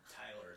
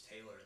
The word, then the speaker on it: Taylor: It's not Tyler, it's Taylor. It's pretty easy to pronounce.